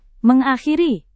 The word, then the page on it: mengakhiri: Indonesian